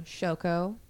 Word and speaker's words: Shoko: Shoko, S H O K O, Asahara, A S A H A R A.